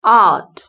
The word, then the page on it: art: British English